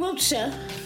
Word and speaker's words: Wiltshire: You know, a lot of the people that we hailed as Wilkshires. – Stephen Wiltshire.